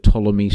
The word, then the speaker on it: Ptolemy: Now, for those of you who've read a little bit about Egyptian history, you'll be aware that Ptolemy starts with a P, but it's a silent P.